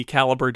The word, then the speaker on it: Calibre: And listener Jeff points out that in the Calibre documentation, it makes it clear that it's pronounced Calibre and not Calibre.